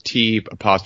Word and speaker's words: T'Challa: And I'm probably pronouncing that wrong. It's T'Challa. – That sounds right.